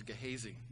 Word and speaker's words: Gehazi: And I looked at the pronunciation, it looks like it's Gehazi, not Gehazi, or Gehazi. Long A, long I sound.